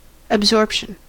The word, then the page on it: absorption: American English